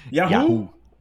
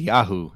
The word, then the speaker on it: Yahoo: No, you're saying the A short. It's supposed to be a long A. – Yahoo. – Oh, Yahoo. – What? First of all, there's only one A and two O's. So what the fuck are you telling me? I'm shortening the A. I'm putting the A in this place. – No, like pronunciation wise, it's supposed to be ya, not yay. Yay is a short A. Ya is a long A.